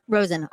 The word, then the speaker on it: Rosen: Rosen, R-O-S-E-N. – R-O-S-E-N.